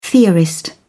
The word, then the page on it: theorist: British English